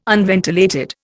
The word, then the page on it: unventilated: Indian